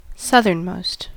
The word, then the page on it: southernmost: American English